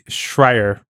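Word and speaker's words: Schreier: How do you say his last name, by the way? Schrier, right? – It is Schrier.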